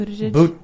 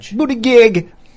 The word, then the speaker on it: Buttigieg: Buttigieg. – Buttigieg.